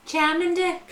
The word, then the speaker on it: Charmander: But I don't think that's how it's pronounced, is it? Is it called a Charmander? – Charmander, that's what it's called, yeah.